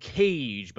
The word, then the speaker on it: Kaaje: It's the band Cage, but it's spelled K-A-A-J-E.